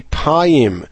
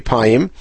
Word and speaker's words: payim: And the proper pronunciation probably would be payim. But in any case, that is obvious because a yod was never used in early times as a vowel. A yod was always used as a consonant. And therefore, if it's spelled pe yod mem, it must be payim.